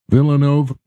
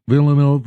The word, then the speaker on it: Villeneuve: Villeneuve, Villeneuve, Villeneuve, Villeneuve, Villeneuve.